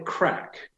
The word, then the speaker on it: craic: in Ireland they call it crack. It's spelled slightly differently, C-R-A-I-C.